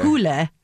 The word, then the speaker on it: Hole: In Norwegian, apparently that's pronounced Harry Hole. – Okay. – Hule. Harry Hule. So, okay, it's not Harry Hole in Norway.